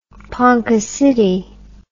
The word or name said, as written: ponca city